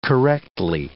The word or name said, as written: correctly